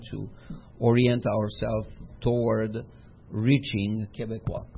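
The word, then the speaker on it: Québécois: Quebecois is the right pronunciation of Quebecers.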